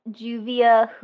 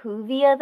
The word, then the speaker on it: Juvia: You also call Juvia Juvia, though? – It makes sense to be a soft J.